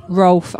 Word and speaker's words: Rolfe: So did I say your last name correctly, Rolf? – Rolf, R-O-L-F-E, yeah.